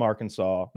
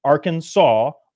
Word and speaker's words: Arkansas: And they said Arkansas, and the the French spelling for aw is as, I suppose. – Mm. – But uh, but it became Arkansas law to pronounce it that way out of respect to those Native, Ameri- Native Americans and the early settlers um, in like 1881, I think, somewhere around then.